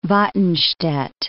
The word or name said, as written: Watenstedt-Salzgitter